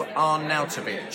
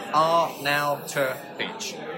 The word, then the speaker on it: Arnautovic: The West Ham player is pronounced Marco Arnautovic. Arnautovic.